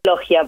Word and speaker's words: Loggia: Yo le digo Loggia, pero estaba muy bien como vos le dijiste Loggia porque es italiano.